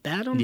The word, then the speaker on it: Badham: And John, I think that's how you pronounce the name, Badham? – Yeah. – It's B-A-D-H-A-M.